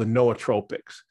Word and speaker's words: nootropic: But along the way, I got really fascinated with a particular small section of all of those botanicals called the nootropics. Nootropics, which for those of that are not familiar with that term, is spelled N-O-O, tropic, but it's with all long O's, so it's nootropic.